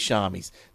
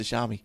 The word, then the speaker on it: Xiaomi: Best way to pronounce Xiaomi is to think of Xiaomi and then pronounce the first words as if it were shower.